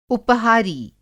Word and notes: उपहारी: Hindi

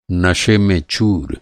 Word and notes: चूर: چور

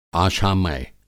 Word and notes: आशामय: Hindi

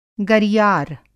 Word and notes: गरियार: Hindi

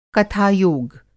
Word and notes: कथायोग: Hindi

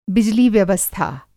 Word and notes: बिजली व्यवस्था: Hindi

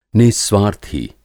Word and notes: नि:स्वार्थी: Hindi

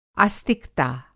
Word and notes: आस्तिकता: Hindi

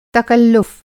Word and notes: तकल्लुफ़: تکلُّف

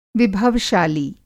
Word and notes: विभव-शाली: Hindi

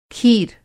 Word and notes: खीर: kheer; کِھیر

